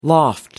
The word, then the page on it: loft: American English